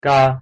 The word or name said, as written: 嘎